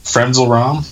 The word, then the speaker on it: Frenzal Rhomb: Is it Frenzal Rhomb? Is that how you say it? – Frenzal Rhomb. Yeah.